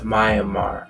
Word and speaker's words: Myanmar: It's Matt, Myanmar.